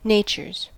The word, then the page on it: natures: American English